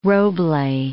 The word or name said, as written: roble